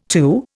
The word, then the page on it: too: Indian